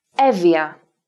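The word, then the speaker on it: Εύβοια: then we don't pronounce the V or F sound twice or longer. We pronounce it once, as if the Ypsilon of the double vowel combination wasn't there. Listen again. Evia.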